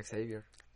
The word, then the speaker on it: Xavier: Aunque se pronuncia Xavier. Xavier. Xavier. Sí, como el de los Javier.